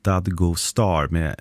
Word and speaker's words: Dadgostar: Efternamnet Dadgostar uttalas ungefär Dadgostar med betoning på första och sista stavelsen.